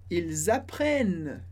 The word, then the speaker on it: apprennent: Ils apprennent l'italien. Ils apprennent l'italien. Atenção só aqui com a pronúncia. A gente não pronuncia o NT do final, mas o N que vem antes, sim.